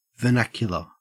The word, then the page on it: vernacular: Australian English